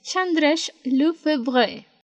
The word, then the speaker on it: Chandresh Lefebvre: Ah, espera, si le puedo preguntar a Google cómo se pronuncia. Ok, entonces, según Google Traductor, su nombre es Chandresh Lefebvre.